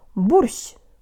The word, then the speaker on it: борщ: The tongue is low for bo but high for sh, so it has to travel fast from low to high in one syllable. Let's go. Jo tang lips. Borsh.